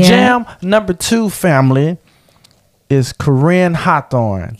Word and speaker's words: Corinne: Yes, Corinne. – I said it right. – Yeah. – I always want to say what I always call her. Corn. – Something corn like that. Hawthorne. But it is Corinne.